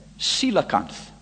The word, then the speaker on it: Coelacanth: Well, you pronounce that Coelacanth.